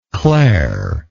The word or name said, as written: claire